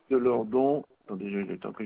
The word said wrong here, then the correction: Lourdes: De Lourdes, Lourdes, comme ça se prononce, Lourdes.